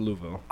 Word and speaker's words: Louisville: It's pronounced Louisville.